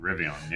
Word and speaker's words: Rivian: I think I'm saying that right. – Yep, I believe that's it. Rivian, yep.